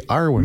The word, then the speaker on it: Arwyn: Arwen? How do you spell it? – A-R-W-Y-N. – Okay, Arwen.